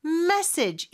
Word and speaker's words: message: 메시지는 message 이름절에 강세가 있고요.